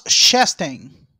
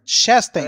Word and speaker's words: Chastain: O Ross, como é que é o nome da pronúncia? – Ross Chastain. – Chanting, exatamente. – Chastain.